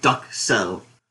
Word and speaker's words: duxelles: It's Gordon Ramsay's signature dish, and he always says Duxelle. Like, pronounces duck and sell. – Duxelle.